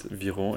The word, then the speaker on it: Viron: Um, and you pronounce it Viron in French or Viron in English. It actually works in both languages.